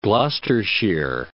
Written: Gloucestershire

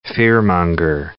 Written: fearmonger

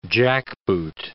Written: jackboot

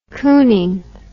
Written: Kooning

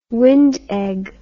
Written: wind egg

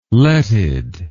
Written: letted

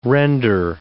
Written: render

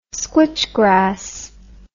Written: squitchgrass

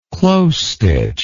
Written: close-stitch

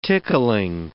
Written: tickling

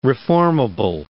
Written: reformable

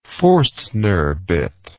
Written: Forstner bit

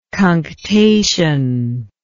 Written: cunctative

